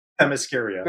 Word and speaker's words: Themyscira: That's Themyscira. – Themyscira.